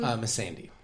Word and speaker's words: Missandei: if you've seen Game of Thrones, is it Missandei? How do you say her name? – Missandei.